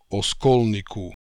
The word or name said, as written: Oskolnikoo